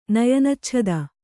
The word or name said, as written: ನಯನಚ್ಛದ